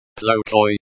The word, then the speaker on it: плохой: Pronounced, plokoi, is the word, bad, and is used as an adjective to describe the noun, they.